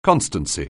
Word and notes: constancy: anglais

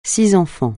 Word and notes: six: francais

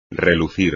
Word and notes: relucir: espagnol

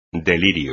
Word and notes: delirio: espagnol

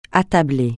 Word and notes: attabler: francais

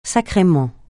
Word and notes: sacrément: francais

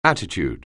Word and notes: attitude: anglais

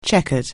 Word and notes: chequered: English

